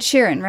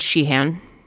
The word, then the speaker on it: Sheeran: Ed Sheeran, right? – Yep. – How did you say it last time? – I don't know. I think I said Sheehan. And you were like, no.